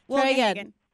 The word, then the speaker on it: Wilgehagen: Did I say it correctly? Oh, close. – My God. I didn't? No. Come on, man. – Well, I'll give it to you. – Come on. – Wilgehagen. – Say it again.